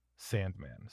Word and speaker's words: S&MAN: And Sandman is spelled S&MAN, Sandman. So it looks like S&M Man, but it's Sandman.